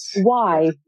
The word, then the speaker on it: Du Bois: He was a stickler for his name pronunciation. He would repeatedly remind people who mangled his last name. It was pronounced Du Bois in the English form.